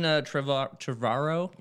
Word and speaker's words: Trevorrow: Yeah, Colin Trevorrow, that's how you say his name, he has stepped down as director.